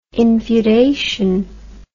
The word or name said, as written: infeudation